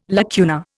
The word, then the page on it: lacuna: Indian